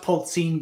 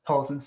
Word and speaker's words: Polzin: Oh, you pronounce it Polzin, but everyone says Polzin, so it's fine.